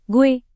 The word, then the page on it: gue: Indonesian